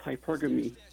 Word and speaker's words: hypergamy: You said it wrong. It's hypergamy.